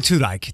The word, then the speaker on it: Tudyk: Alan Tudyk, did I pronounce that correctly? – I believe so.